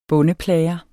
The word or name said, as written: bondeplager